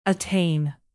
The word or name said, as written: attain